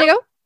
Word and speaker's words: Bendigo: Bendigo. – Bendigo.